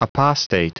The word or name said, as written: apostate